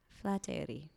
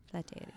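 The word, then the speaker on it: Flateyri: Yeah, and we kind of pronounce almost everything that we write. But A, these two letters are the one sound, Eiri. – Flateri. – Yeah. – Flateri.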